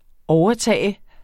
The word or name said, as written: overtag